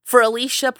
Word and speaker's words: Fearlisha: and comedy and then um Fearlisha presents is f-e-a-r-l-i-s-h-a uh, but we had to change the spelling a little bit to make it pronounced.